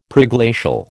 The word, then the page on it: Preglacial: American English